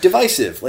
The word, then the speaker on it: divisive: Like, divide, divisive. Like, it's a long I. Like, don't overthink it.